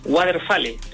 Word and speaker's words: Waterfall: Mira, estoy vendiendo una caja acústica activa, un parlante, marca Waterfall, te lo estoy pronunciando tal como se lee.